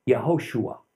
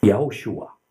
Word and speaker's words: Yehoshua: And in Hebrew, Joshua is Yehoshua. Yehoshua. There we see that that middle O added effectively adds another syllable to his name, but we've contracted it to Yeshua.